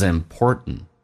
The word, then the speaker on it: important: Finally, it is important to remember that it is 用降调来读 it is，还有连音，跟 m 可以有一个连音叫 it is important。 It is important，而且 important 这个单词在美式发音当中 t 跟 n 组合在一起，最后一个 t 不发音，这叫略音。